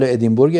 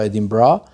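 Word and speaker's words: Edinburgh: حالا فیلم جدیدش مردشوی یا مردشور توی فستیوال ادینبورگ اسکاتلند به تلفظ خود بریتیشا ادینبرا پذیرفته شده، که میدونید فراتر از یک فستیوال سینمایی، یک اتفاق فرهنگی خیلی فرامدرن توی بریتانیاست هر سال.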